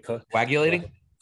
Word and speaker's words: coagulating: collagating, you know, is it right? Did I say that right? – Coagulating? – Yeah, there you go.